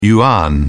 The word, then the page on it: yuan: British English